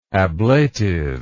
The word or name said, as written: ablatively